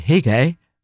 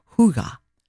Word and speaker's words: hygge: So I are going to spend, as you just pronounced it, Hige, but technically, even though I cannot speak Danish, cannot pronounce it, but it's pronounced more Huga, not Hige.